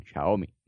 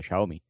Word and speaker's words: Xiaomi: En la página oficial de, Xiaomi España, de Xiaomi, perdón, Xiaomi. ¿Sí? – don Hugo Barra nos ha enseñado a pronunciarlo, Xiaomi.